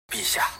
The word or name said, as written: bixia